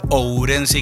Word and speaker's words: Ourense: Primero, eh, el curso para tener que pronunciarlo, porque tuvimos mucho tiempo practicando para decir Ourense y que el diptongo no nos mate.